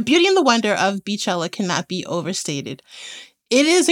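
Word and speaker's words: Beychella: They're entitled to their wrong pronunciation. It is Beachella because her name is Beyonce. Beachella is not a thing. She's the Queen Bee, not Queen Bey. And B-E-Y, though looks like Bey, is B.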